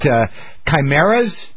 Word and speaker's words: chimeras: Because, you know, I'm reading this article, you know, about cloning, about gene splicing, about chimeras. Is that how you pronounce it? Chimeras? – Uh-huh.